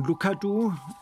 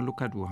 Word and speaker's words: Lucadou: Also mein Name ist Walter von Lukadu und Lukadu spricht man aus wie Kakadu, nur Lukadu halt.